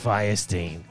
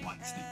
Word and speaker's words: Weinstein: The ghost of Harvey Weinstein. – I'm pretty sure it's pronounced Weinstein.